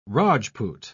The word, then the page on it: rajpoot: British English